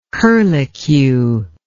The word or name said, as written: curlycued